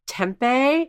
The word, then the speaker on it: tempeh: She didn't mention tempeh.